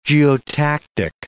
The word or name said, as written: geotactic